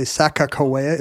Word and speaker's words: Sacagawea: Well, it's Sacagawea. It's actually Sacagawea is closer to the I see. – Whatever the tribal pronunciation is, I guess.